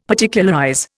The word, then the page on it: Particularize: Indian